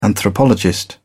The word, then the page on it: anthropologist: British English